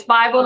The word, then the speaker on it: Bible: You'd be surprised how many people say Bible, but it's Bible, like the good book.